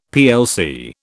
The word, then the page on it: plc: British English